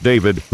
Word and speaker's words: D4VD: And the singer, D4VD, pronounced David, whose hit is called Romantic Homicide, is reportedly stonewalling the sleuth searching for the truth.